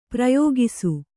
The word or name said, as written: ಪ್ರಯೋಗಿಸು